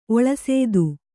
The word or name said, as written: ಒಳಸೇದು